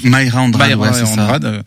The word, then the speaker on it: Mayra Andrade: Mera Andrade, Mayra Andrade. – Sur la prononciation, j'avais un doute.